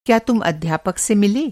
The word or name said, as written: मिलना